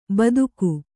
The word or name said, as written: ಬೆದಕು